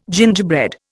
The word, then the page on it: gingerbread: Indian